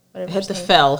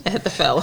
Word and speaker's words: Ahithophel: Ahithophel. Fel. P H F sound. Ahithophel.